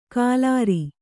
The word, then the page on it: ಕಾಲಾರಿ: kālāri